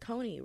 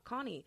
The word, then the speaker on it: Coney: And then I was like, oh, like every time I wrote out the word Coney, people would always pronounce it as Connie. And I was like, it's not Connie.